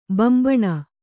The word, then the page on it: ಬಾಂಬೆಣ್: Kannada